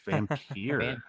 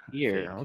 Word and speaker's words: Vampyr: Vampire? Oh, man. – It's Vampyr. – Vampyr.